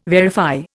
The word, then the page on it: verify: Indian